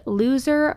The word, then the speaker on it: Luzer: His name isn't Loser as in L-O-S-E-R. It is pronounced that way, but it is spelled L-U-Z-E-R. So as I'm saying his name throughout this, I'm not calling him a loser. That literally is just how you pronounce his name.